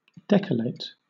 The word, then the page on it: decollate: British English